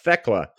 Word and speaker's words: Thecla: If you've heard of Thecla, am I saying that right? T-H-E-C-L-A, Thecla.